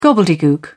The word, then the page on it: gobbledegook: British English